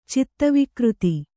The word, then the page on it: ಚಿತ್ತವಿಕೃತಿ: kannada; citta vikřti